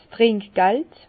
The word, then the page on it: 's Trinkgeld: Bas-Rhin; Herrlisheim